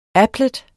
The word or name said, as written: applet